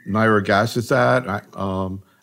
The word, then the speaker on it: nirogacestat: Maybe we ought to start talking about the these new agents, particularly nirogacestat.